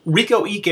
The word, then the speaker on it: Reiko Ike: Riko Ike. – Riko Ike is how I've heard it pronounced, but I wanted to make sure that I wasn't, leaving out, you know, a silent Q or something.